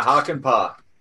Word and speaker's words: Hakanpää: Yeah, Hakanpää.